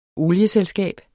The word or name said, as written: olieselskab